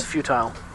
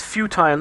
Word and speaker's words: futile: Indeed, I like that, even though I hate the way he pronounces futile. It's futile, not futile. – Yeah. Well, Data always had his own way of saying things. – Indeed. But you'd think that Data would know how to pronounce futile. – Yeah. – But hey, that's just a small thing. – Although the holographic doctor pronounces it futile as well. – Yeah, Americans.